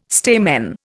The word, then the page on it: stamen: Indian